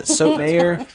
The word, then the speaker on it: Sotomayor: Sonia Sotomayor? Yeah, Sotomayor.